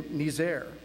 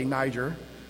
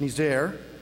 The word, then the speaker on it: Niger: You recently had a coup in Niger. Yes, that is the proper way to pronounce it. I looked it up because I wasn't 100% sure. But some might say Niger. It's Niger. Yeah, there you have a democratically elected president where you just had a military coup occur.